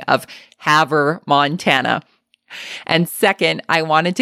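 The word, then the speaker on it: Havre: You may or may not have caught it, but in the intro, I said that Rochelle was from Harve, Montana, when in actuality, it is pronounced Haver, Montana.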